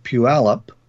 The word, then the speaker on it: Puyallup: This is from Lisa, and Lisa moved to Puyallup, as she was taught to pronounce it, due to the wind blowing. – That is correct.